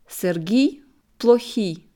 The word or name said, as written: Serhii Plokhy